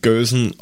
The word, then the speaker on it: Gelse: Gelse geschrieben, Gösen ausgesprochen.